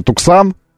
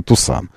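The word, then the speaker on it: Tucson: Туксан, а тем более Тусан, да, когда ты видишь, что написано же Туксан, а произносить нужно Тусан.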